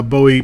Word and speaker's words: Bowie: So going to help us with the Bowie podcast. And actually, I just said it wrong again. – It's Bowie. – Yeah, it's Bowie. It took me a little while to get it together.